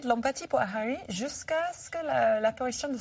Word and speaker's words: Harry: Harry,